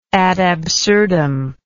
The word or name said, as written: ad absurdum